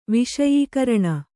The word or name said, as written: ವಿಷಯೀಕರಣ